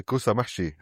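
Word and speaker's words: kusa mahshi: Today, I'm joined by, who participated in our Bring a Plate video series last year, where he introduced us to kusamashi. Am I saying that right? – Yeah, kusamashi.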